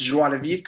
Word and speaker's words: Joie de Vivre: You actually pronounced Joie de Vivre correctly.